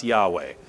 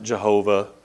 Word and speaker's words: Yahweh: And that word Lord to a Hebrew audience is going to immediately conjure up images of the word we would pronounce Yahweh. Back in the day, we used to pronounce it Jehovah.